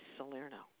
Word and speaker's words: Salerno: Last name is Salerno. – Spell that for me, please. – S-A-L-E-R-N-O.